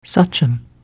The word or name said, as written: sachem